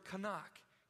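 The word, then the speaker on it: kanak (חנך): It's the word kanak. Can everyone say that with me? Can you pull that up on the slide? Here's how you say it. Can you say that? Kanak.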